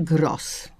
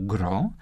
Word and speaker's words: gros: Czy pożyczki, tu akurat jest pożyczka francuska, należy wymawiać zgodnie z tradycją danego języka obcego, w tym wypadku gro, czy też należy wymawiać tak, jak jest ta pożyczka zapisana, a zatem gros.